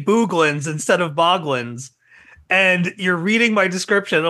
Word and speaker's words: Boglins: It's Boglins, not Booglins.